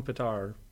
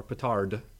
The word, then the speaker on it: petard: Hoist with your own petard or petard. It can be pronounced either way.